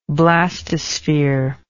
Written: blastosphere

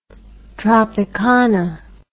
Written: tropicana

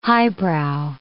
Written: highbrow

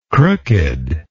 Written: crookedness

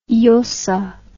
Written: Llosa

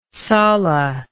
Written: sala

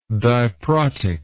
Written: diprotic

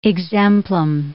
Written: exemplum

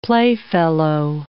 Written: playfellow

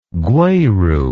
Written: gweru

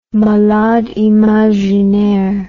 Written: malade imaginaire

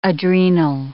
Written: adrenal

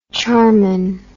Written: charmin

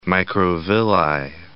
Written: microvilli

